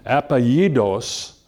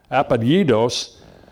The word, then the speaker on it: apellidos: And then they have what they call, and if I can pronounce this right, I know that the two L's in Spanish have a Y sound, so apellidos, I think that's it, apellidos, that's a term for last names.